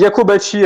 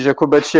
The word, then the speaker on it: Jacobacci: Alors, je crois qu'on dit Jacobacci. Alors, nous, on dit Jacobacci à la, française, mais je pense que les Italo-Suisses, c'est plus du Jacobacci.